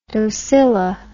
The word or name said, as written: docila